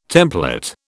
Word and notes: templet: British English

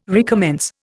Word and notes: recommence: Indian

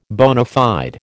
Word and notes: bona fide: American English

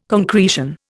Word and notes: concretion: Indian

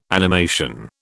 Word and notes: animation: British English

